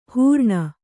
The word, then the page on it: ಹೂರಣ: hūraṇa